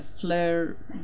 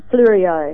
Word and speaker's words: Fleurieu: The Fleurieu. – Fleurieu.